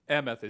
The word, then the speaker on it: emeth: Emeth is E-M-E-T-H is usually how it's pronounced. Emeth.